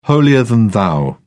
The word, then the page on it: holier than thou: British English